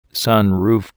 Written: sunroof

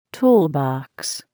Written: toolbox

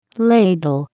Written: ladle

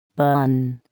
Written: bun

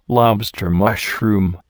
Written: lobster mushroom

